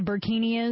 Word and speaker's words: burkini: We have the latest French police enforcing a burkini, right?